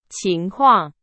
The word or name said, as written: 情况